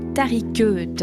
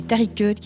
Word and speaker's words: Taricheutes: Dans l'ancienne Égypte, qui étaient les Tariqeutes? Les Tariqeutes qui s'écrit T-A-R-I-C-H-E-U-T-E-S.